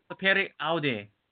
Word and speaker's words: Sapere aude: It's a sapere, do I? – Sapere aude. Dare to know. Not, don't just know it.